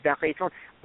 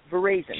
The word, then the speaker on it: veraison: If you speak French, it is veraison. But if we're American, it's pronounced veraison, hence veraison vineyards and winery.